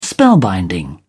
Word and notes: spellbinding: British English

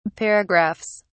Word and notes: paragraphs: British English

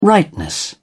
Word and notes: rightness: British English

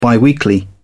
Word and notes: biweekly: British English

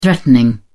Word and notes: threatening: British English

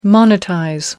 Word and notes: monetize: American English

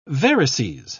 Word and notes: varices: British English

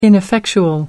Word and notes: ineffectual: American English

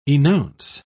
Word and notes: enounce: British English

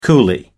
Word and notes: coolie: American English

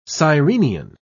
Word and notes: sirenian: British English

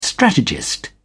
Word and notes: strategist: British English

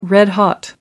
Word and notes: red-hot: American English

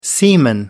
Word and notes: seaman: American English

